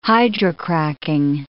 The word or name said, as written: hydrocracking